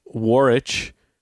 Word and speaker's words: Warwick: Or the place, Warwich. It's pronounced Warwich, but it's spelled Warwick.